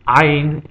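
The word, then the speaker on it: Ayin (עין): Ayin is also silent, but Ayin you pronounce by constricting your vocal cords. So if there's a vowel attached to ayin, you would say the vowel while constricting your vocal cords in kind of like a guttural gulping sound.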